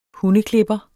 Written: HUNDEKLIPPER

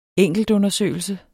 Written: enkeltundersøgelse

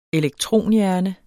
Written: elektronhjerne

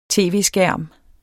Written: tv-skærm

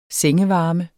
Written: sengevarme

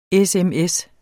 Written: sms

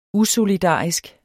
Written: usolidarisk

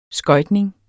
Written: skøjtning

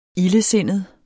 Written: ildesindet

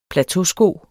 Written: plateausko

